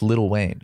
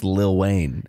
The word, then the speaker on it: Lil Wayne: It's pronounced Little Wayne. – It's pronounced Lil Wayne. – It's spelled L-I-L, but it's pronounced Little Wayne.